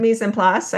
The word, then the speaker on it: mise en place: I, am I saying it right? It's French. I don't speak French, so uh, when they say oh, mise en place, right? Is that how it's said? Mise en place.